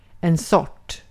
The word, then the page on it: sort: IPA: [sɔʈ]